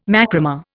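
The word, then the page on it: macramé: Indian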